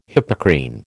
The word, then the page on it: Hippocrene: American English